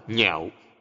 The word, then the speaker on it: nhạo: Chữ lạc đọc là nhạo, nghĩa là ưa thích. Nếu đọc là lạc sẽ là vui sướng.